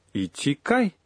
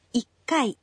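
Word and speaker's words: иккай (一階): «Один ичи» — значит «ичи кай». – Вы почти угадали, но правильный ответ — «иккай», «ичкай». Трудно произносить, поэтому говорят «иккай».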